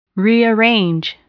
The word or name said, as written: rearrange